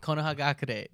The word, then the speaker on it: Konohagakure: Konohagakure.